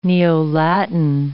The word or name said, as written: Neo-Latin